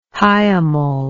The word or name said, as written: hiemal